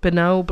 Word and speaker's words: Benoit: I know it does not look like it from the way that it is spelled, but his name is Benoit Blanc.